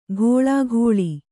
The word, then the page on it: ಘೋಳಾಘೋಳಿ: Kannada; ghōḷāghōḷi